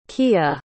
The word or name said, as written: keeya